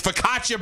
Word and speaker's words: focaccia: I was like, well, how do you say it? She's like, it's focaccia bread.